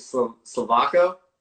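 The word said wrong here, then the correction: Slovácko: Slovasko. Yeah. It was very Slovasko. Close. You almost good pronunciation.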